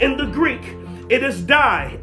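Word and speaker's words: die (δεῖ): But now the word need in the Greek, the word need in the Greek is die. Like the English word die, D-I-E, it's die. But get this, it's not spelled D-I-E. It's spelled D-E-I.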